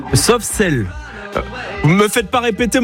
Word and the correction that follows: Soft Cell: Chloé, comment prononce? J'appelle... – Soft cell. – Comment? – Soft cell. – Soft cell. C'est mieux prononcé, Michel, on est d'accord.